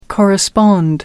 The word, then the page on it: correspond: American English